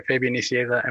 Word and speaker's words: Fabian Nicieza: Fabian Nicieza.